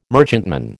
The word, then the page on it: merchantman: American English